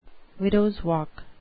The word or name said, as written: widows walk